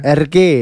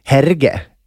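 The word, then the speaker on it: Hergé: Hur uttalas det? Uttalas det RG? – RG, ja det låter bra när du säger det. – Om G uttalas, det vet jag inte. – Jag tror inte man säger som man sa när man var liten när man läste dem där. Herge, det tror jag inte.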